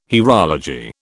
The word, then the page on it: hierology: British English